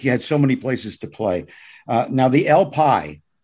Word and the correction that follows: Eel Pie Island: I never was in it, but I've been by it. Did I pronounce that right? – No. It's Eel Pie Island.